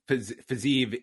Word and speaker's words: Fiziev: Yeah, Rafael Fiziev is 9-1. And yes, it's I double checked pronunciation here, so we're on the right track here.